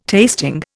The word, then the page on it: tasting: Indian